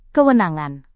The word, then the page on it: kewenangan: indonesian